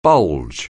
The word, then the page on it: bulge: anglais